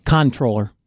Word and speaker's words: comptroller: Controller. Nope. Don't say the P.